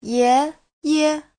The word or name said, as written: ye2ye5